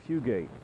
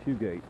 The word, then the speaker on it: Fugate: But it's what the Bible says about raising children, and the author is Fugate, F-U-G-A-T-E, I think it's the way it's pronounced.